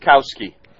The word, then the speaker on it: Gostkowski: Gostkowski, but the T is silent or the K, according to Brian Lowe.